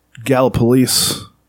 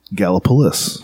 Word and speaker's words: Gallipolis: They're from uh, Gallipolis. They pronounce it Gallipolis. Gallipolis, yeah. We say Gallipolis. So I think actually the proper pronunciation would probably be Gallipolis or something like that.